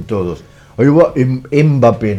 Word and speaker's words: Mbappé: una persona conocida, cercana a mí, dice, che, ¿cuántas patadas le pegaron a MP Mape? No se dice así, se dice Mbappé.